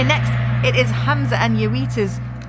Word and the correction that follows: Jowita: I've said her name wrong, haven't I? Hamza and Jowita.